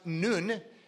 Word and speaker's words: Nun: So Nineveh just happens to be, in its term, in the Phoenician language, the letter N, which is pronounced Nun, actually means something.